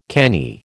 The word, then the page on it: canny: American English